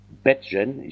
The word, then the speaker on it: Badgegen: Das heißt Badgen. Ich habe immer Bart gelesen.